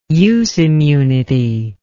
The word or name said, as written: use immunity